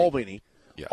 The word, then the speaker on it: Albany: You got Stanford at home, and then okay, what's the correct way to say it? – Albany, Albany, Albany, Albany, Albany, yeah.